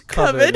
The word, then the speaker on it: Coven: So what he wants to do is complete a short that he already started called Coven, which is how he pronounces Coven, because it's an accent.